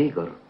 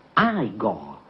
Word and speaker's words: Igor: Tu devi essere Igor. – No, si pronuncia Igor. – Ma mi hanno detto che era Igor.